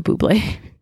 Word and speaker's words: Bublé: But I mean, they pretty much have the best ambassador that they could, Michael Bublé. – He's actually really funny on those commercials. And they're like, oh, look, it's a bubbly. He's like, it's pronounced Bublé.